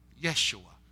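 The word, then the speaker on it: Yeshua (ישוע): In Aramaic, the name Joshua is pronounced Yeshua, where we get the English form, or the Aramaic form of Jesus.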